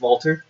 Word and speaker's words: Walter: Can you say it right? It's Walter. – I can't do that. It's like Mr. Vink with a V.